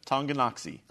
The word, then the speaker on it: Tonganoxie: Tonganoxie.